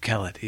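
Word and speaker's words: Quelet: So I'm going to call him Steve Kellett, even though it's Q-U-E-L-E-T. So I'm sure there's a French pronunciation for that and whatever else.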